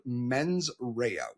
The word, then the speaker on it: mens rea: So I'm really not the best at Latin, but I believe the way it's pronounced is actually mens rea, which when loosely translated means malicious intent.